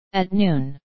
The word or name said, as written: at noon